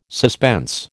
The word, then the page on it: suspense: American English